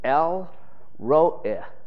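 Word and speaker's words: El Ro'eh (אל רואי): This name today, pronounced in the Hebrew, El Ro'eh, Ro'eh, it's a short I, eh, I, it's kind of like I. I-H, eh, if you can make that sound, Ro, R-O, El Ro'eh.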